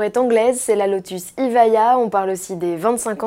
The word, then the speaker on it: Evija: C'est l'Evija, prononcé Ivaya.